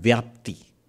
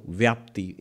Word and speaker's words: vyapti: He brings in a term called vyapti. This may be one of the few times he does so, vyapti. In English, you will spell it as V-Y-A-P-T-I.